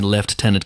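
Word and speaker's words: lieutenant: Even down to pronouncing left tenant correctly this week, which I'm sure pleased you enormously, James, being the massive pedant that you are.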